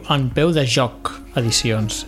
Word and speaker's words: En Peu de Joc Edicions: Es diu En Peu de Joc Edicions, és a dir, En Peu de Joc en singular, edicions en plural, que jo ho he pronunciat malament algun cop.